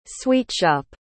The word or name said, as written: sweetshop